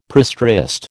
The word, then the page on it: prestressed: American English